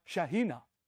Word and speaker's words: Shekinah: The Shekinah, glory of God. Or if you don't like that pronunciation, they say the Shekinah, glory of God. Really, it's more like Shekinah.